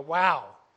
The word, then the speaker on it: Vav: This is known as the Vav, this modern-day Ashkenazi Hebrew. Now, according to Hebrew linguists, Hebrew scholars, they say the Vav was originally a wow, and pronounced as a W.